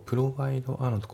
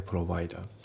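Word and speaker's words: provider: ね、本番では聞き取れる音から内容を追うと、ね、で、まあ、ただ練習では全てをね聞き取れるように練習していくと、まあ、そうすることでねリスニング力が上がっていくんで、うん、であと発音ですけど、プロバイドアのところがリンキングでプロバイダーですね。プロバイドアじゃなくてプロバイダー、うん、あとその下のセンドイットのところがリンキングでセンデッド、ね、センドイットじゃなくてセンデッド、あと it の t が抜けますね。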